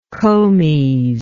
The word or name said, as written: comes